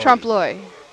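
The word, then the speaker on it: trompe-l'œil: T-R-O-M-P-E-L-O-E. – Trump Loy.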